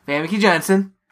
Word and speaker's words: Famke Janssen: Famke Janssen. – Is that how you say it? – Yeah, basically.